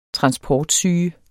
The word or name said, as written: transportsyge